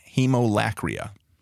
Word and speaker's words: haemolacria: Hemolacria.